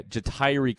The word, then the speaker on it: Jahtyre: I was going to say, man, those names sound so, like, you could, like, when you, I was glad you said, is it Ja'Tyree Carter? Is that how you say his name? – No. That is, yeah, Jahtyre Carter.